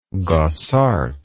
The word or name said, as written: Gossaert